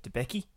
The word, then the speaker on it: Debicki: I think like her surname might be pronounced like Debetsky or something. – Yes. Elizabeth Debicki.